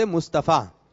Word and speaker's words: مصطفیٰ: اور مصطفیٰ مصطفیٰ کرتے ہیں تو صحیح تلفظ ہے مصطفیٰ.